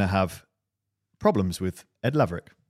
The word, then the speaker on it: Laverack: How do you say his surname? Laverack. – Laverack. – Laverack. Laverack. Ed Laverack.